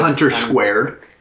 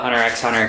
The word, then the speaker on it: Hunter x Hunter: Hunter Squared. – Yeah, as I'm sure some of you will call it. I call it Hunter x Hunter, because fuck you, the X is there. I'm going to pronounce it.